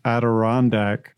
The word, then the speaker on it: Adirondack: Now, recently I have seen one of our Facebook group members posted a photo of some Adirondack.